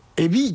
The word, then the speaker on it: EBI01: アルファベットで EBI01 って書いてエビ 1 って読むらしいです、うん、